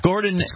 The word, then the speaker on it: Gordan: Jordan, it's Jordan with a G, or Gordon, I guess. – Okay. – Gordon. Van Gilder, but it's spelled differently, G-O-R-D-A-N.